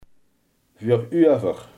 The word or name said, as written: voorover